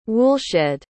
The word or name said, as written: woolshed